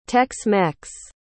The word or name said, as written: tex-mex